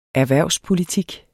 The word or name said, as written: erhvervspolitik